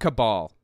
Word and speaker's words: cabal: And by the way, it's pronounced cabal. The root word being, or the the etymology of it, coming from the word Kabbalah, Jewish mystic practice, or whatever, some Jewish mysticism thing, or whatever.